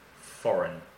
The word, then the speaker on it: foreign: And be careful of the pronunciation of foreign because the spelling is a little strange. F-O-R-E-I-G-N, foreign, foreign currency.